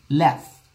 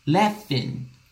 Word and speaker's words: laugh: Laugh, laugh, laugh. O curioso é a pronúncia. Tá? Então, se escreve ali, laug. Aí você fala laugh, laugh, laugh, laugh. Como se fosse com F. Laugh, laugh.